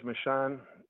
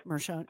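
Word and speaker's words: Merchan: You know that I think, and you may disagree with this, but Jack Smith and Judge Michonne, I think Michonne. – That those two, yes, I'm sorry if I mispronounced it.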